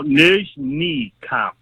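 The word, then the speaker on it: Nizhnekamsk: Nizhny Kamsk. – No, Nizhny Kamsk, like Nizh, and then your knee, and Kamsk.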